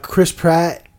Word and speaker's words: Chris Pratt: Chris Pratt, am I saying his name right? – Yeah.